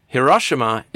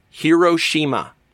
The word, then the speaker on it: Hiroshima: In America, Hiroshima is often pronounced Hiroshima. And in Japan, it's pronounced roughly Hiroshima.